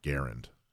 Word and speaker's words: Garand: actually, it's pronounced Garand.